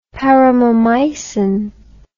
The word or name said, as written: paromomycin